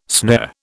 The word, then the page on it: snare: British English